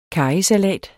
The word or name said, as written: karrysalat